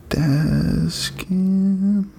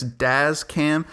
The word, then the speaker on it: Dazz Cam: I want to shout out Dazz Cam. Let me pull my phone up and make sure I said that correctly. Uh, Dazz Cam. Yes, Dazz Cam, D A Z Z C A M, Dazz Cam on the App Store.